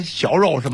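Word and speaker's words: 肴肉: 那过去啊，长江中下游啊，就是做猪肉，有三个特别有名的菜，一个呢就是镇江的，你肯定吃过了，你们读成小肉是吗？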